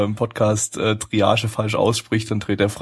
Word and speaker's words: Triage: aber den ersten Anime, den wir uns heute Abend zu Gemüte gezogen haben, das war Dreierk X. Ja? Nee, ich so das nicht ausgesprochen. – Äh, wie wird's denn richtig aus? Äh, Triage. Triage. Äh, Triage. – Das ist Triage. ein französisches Wort.